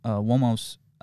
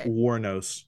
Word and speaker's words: Wuornos: But, you know, when something like Aileen Wuornos, I said it wrong, didn't I? – Mm-hmm. But it's okay. – Yeah, Wuornos.